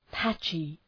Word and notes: patchy: ‘pætʃı

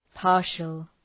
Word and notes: partial: {‘pɑ:rʃəl}

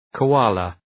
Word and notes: koala: {kəʋ’ɑ:lə}

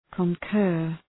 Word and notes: concur: kən’kɜ:r

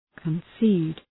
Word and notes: concede: {kən’si:d}

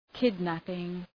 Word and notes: kidnapping: ‘kıd,næpıŋ

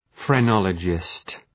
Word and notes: phrenologist: frı’nɒlədʒıst

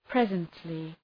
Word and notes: presently: ‘prezəntlı

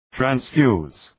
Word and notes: transfuse: træns’fju:z

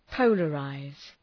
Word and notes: polarize: ‘pəʋlə,raız

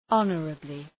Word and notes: honorably: ‘ɒnərəblı

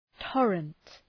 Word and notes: torrent: ‘tɔ:rənt

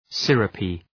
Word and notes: syrupy: ‘sırəpı

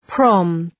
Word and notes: prom: prɒm

